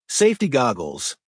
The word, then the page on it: safety goggles: American English